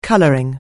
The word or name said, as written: colouring